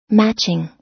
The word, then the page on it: matching: American English